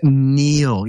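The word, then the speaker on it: Niall: Two of my favorite people, Neil, it's pronounced Neil.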